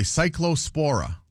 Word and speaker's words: cyclospora: Cyclospora. – Yep. – I think that's how you say it, right? – You do.